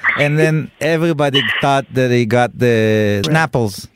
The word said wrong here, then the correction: shrapnel: Shrapnel. – Wow, I like how slow you pronounced it.